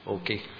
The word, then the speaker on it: Åke: So there's another person in this story whose name is Åke. That's how you say it in Swedish, Åke.